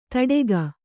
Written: ಥಟ್ಟುಗಿ

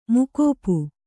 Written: ಮುಕೋಪು

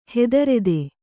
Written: ಹೆದರೆದೆ

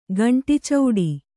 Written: ಗಂಟಿಚೌಡಿ

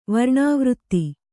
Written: ವರ್ಣವೃತ್ತ

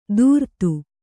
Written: ತುರ್ತು

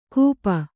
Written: ಕೂಪ

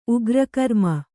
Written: ಉಗ್ರಕರ್ಮ

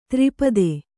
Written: ತ್ರಿಪದೆ

